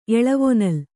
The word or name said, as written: ಎಳವೊನಲ್